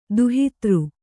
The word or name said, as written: ದುಹಿತೃ